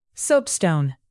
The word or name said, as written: soapstone